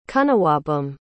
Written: cunnawabum

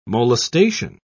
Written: molestation

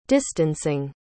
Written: distancing

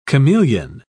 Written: chameleon

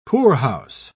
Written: poorhouse

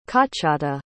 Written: qochata

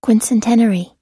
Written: quincentenary

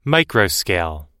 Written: microscale

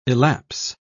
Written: elapse

